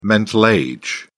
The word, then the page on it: mental age: British English